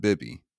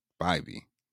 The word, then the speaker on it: Bibee: First and foremost, we had Logan Allen and Tanner Bibee, not Bibby, Bibee. Everybody was confused on the pronunciation.